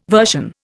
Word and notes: version: Indian

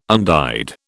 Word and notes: undyed: British English

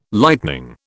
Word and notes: lightning: British English

